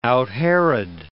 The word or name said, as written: out-Herod